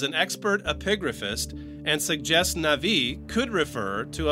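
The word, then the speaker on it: Navi (נביא): When we, in modern Hebrew, maybe, pronounce the name Navi, you don't feel, you don't hear the Aleph, but it was felt, it was pronounced.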